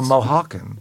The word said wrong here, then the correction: Oaxacan: It's O-A-X-A-C-A-N, and it's pronounced Oaxacan.